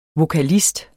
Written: VOKALISTER

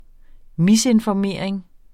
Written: misinformering

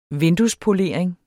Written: vinduespolering

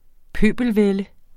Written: pøbelvælde

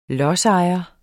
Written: lodsejer

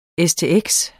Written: stx

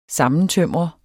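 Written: SAMMENTØMRET

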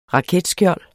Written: raketskjold

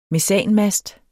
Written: mesanmast

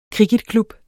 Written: kricketklub

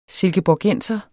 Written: silkeborgenser

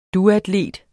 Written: duatlet

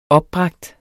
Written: opbragt

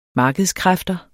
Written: markedskræfter

